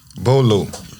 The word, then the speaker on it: Beauxleaux: It's spelled like Boo, B-E-A-U-X-L-E-A-U-X. – Bolo. – That's Bolo? – Yeah.